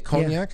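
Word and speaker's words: konjac: That's how you say it, cognac? – Yeah, I think so, yeah. – It's K-O-N-J-A-C. – Yeah. – Cognac plant, eaten in Asia for over 1,000 years.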